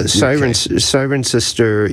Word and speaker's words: Cirencester: Yeah, Cirencester, UK, 1973.